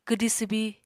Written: kwdi swbi

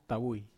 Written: tawui

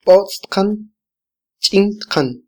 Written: botz tqan - chintqan

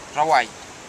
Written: rawai

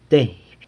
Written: teiby

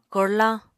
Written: korlañ

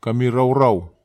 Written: kamiraurau

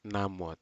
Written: namut

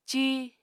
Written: cíi

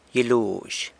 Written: gyiluzh

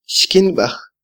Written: xkynbaj

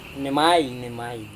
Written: nemai

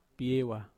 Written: piewa

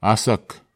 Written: asak

